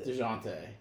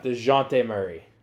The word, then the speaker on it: Dejounte: Yeah, it's DeJounte. – DeJounte. – Yeah. – DeJounte Murray.